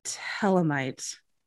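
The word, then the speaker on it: Thelemite: I didn't know much about it, just that he called himself a telemite. A th- it looks like the themalite, but it's she says that the H is silent.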